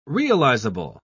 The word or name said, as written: realizable